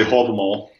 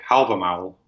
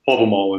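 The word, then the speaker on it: Hávamál: I would say Havamal. – Havamal. – And then a lot of other people would say Havamal, which is the modern Icelandic pronunciation. But Havamal is the reconstructed Old Norse pronunciation.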